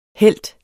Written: helt

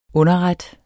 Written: underret

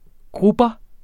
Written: grubber

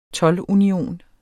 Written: toldunion

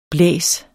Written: blæs

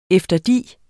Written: efterdi

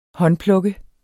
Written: håndplukke

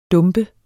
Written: dump

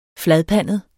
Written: fladpandet

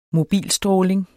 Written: mobilstråling

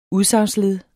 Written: udsagnsled

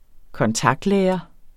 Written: kontaktlærer

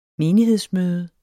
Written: menighed

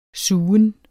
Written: sugen

